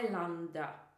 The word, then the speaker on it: islander: So when we have an island, czyli mamy wyspę, an island, osoba, która mieszka na wyspie lub pochodzi z wyspy, jest islander. I pisze się to z S, czyli IS, tak? I tego S się nie wymawia, to jest island o islander.